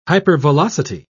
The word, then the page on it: hypervelocity: British English